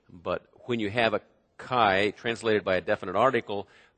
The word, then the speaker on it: kai: And basically put, there's a Greek word that it's translated and it's spelled K-A-I, if you were to transliterate the Greek letters into English letters, chi, and it's translated and.